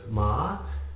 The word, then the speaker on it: Ma'at: And it reads, another variation, it says, Maat, pronounced Maat,